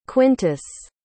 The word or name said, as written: quintus